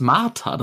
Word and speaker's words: Martha: Und, äh, und sie, ähm, hier Dark, Dark, Dark Martha, nehmt ihn halt äh, mit ja, in einer das nicht Martha, das ist Martha, das wird deutsch ausgesprochen.